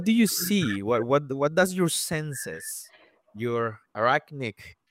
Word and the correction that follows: arachnid: senses, is that how you say it? Arachnic? Arachnid. In Spanish, it would be arachnid.